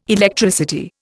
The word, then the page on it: Electricity: Indian